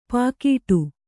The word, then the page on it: ಪೋಗಾಡು: pōgāḍu